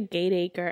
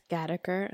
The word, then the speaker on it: Gateacre: Get me when it's like I'm like, oh, I'm going to Gateacre, and they're like, actually, it's Gattaker, and I'm like, I'm like, why bother?